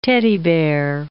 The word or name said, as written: teddy bear